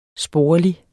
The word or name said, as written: sporlige